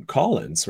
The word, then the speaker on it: Callens: I mean, Collins also, I think Collins' name is based on Collins, right? – Is it C-O-L-L-I-N-S? – Well, his is C-A-L-L-E-N-S, but I think, but it's pronounced that way.